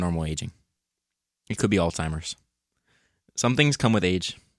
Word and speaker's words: Alzheimer's: It also says pronunciation note, Alzheimer's. Alzheimer's. In case you forgot.